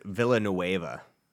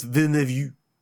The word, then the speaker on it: Villeneuve: I think it's Villanueva. – I believe it's Villeneuve.